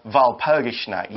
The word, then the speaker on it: Walpurgisnacht: We should actually have mentioned at the start, we use the V sound for Walpurgisnacht. You'll、mm-hmm. read it as a W in the article. We're saying it with a V because that's how the German language is usually pronounced.